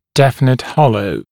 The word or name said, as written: definite hollow